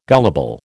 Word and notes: gullible: American English

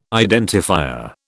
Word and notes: identifier: British English

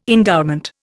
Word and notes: endowment: Indian

